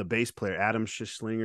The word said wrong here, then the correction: Schlesinger: or something like that. – Schlesinger, yeah.